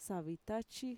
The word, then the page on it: savitachi: Mixtec